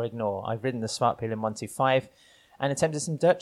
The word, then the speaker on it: Svartpilen: Svartpilen and Witpilen. – Thank you for the correction.